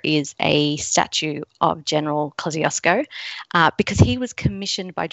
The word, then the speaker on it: Kosciuszko: Our tallest mountain is Mount Kosciuszko, and that's a very Australian pronunciation of a Polish name.